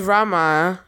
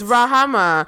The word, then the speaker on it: Rahama: Pronounce how you pronounce it. – I thought it was Rama. – Is it? – It's Rahama.